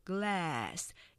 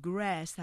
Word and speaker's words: glass: 우리가 보통 glass 이렇게 아, 삼음절로 하기 쉽지만 음절 보시면 glass 이렇게 이름절로 발음되죠. 이 L하고 R 조심하셔야 될게 자, R로 해서 grass 하게 되면 잔디라는 의미가 되기 때문에 glass 그다음에 R grass 발음의 차이로 단어의 의미가 바뀌기 때문에 조심하시고요.